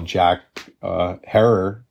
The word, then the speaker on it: Jack Herer: Others love the smell of Jack Herer, rhymes with terror, or train wreck, right?